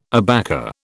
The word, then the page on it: abaca: British English